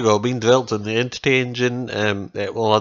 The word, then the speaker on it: NTT: Anyway, yeah, so the game itself, it's been put together using the Entity Engine, which is spelt NTT.